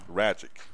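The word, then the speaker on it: Rajcic: Max Rajkic. Rajkic. He's on the mound. – He said his name rhymes with magic. – Yeah. – Rajic.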